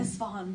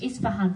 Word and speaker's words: Isfahan: When you were writing, forgive my pronunciation, if Afghanistan Blues, have I said that? – Isfahan Blues. – Isfahan Blues. Sorry, that I knew I had it wrong.